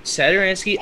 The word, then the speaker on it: Satoransky: Satoransky.